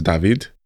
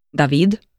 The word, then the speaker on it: David: So I think the, the, the correct pronunciation for it is David. David. My mama calls me David.